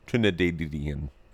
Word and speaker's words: Trinidadian: Am I saying that right? Trinidad. Trinidadian. It's the extra didda that gives it that pop. Trinidadian. Right.